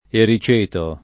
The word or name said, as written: ericeto